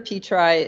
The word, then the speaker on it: Petri: Alexandra Petri is a, and I believe I'm saying that correctly, although it's not the instinctive way to pronounce her last name, which is spelled like Petri dishes, like P-E-T-R-I.